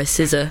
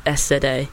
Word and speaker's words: SZA: I think we should play a song called Normal Girl, and it is by SZA, which is just SZA. Which for a long time I was saying it wrong.